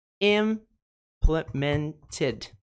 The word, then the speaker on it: implemented: Então, não é implemented. Esse Ted não é a sílaba tônica. Então, esse padrão de pronúncia se aplica. Então, vamos lá. Implemented. Implemented. M é a nossa sílaba tônica.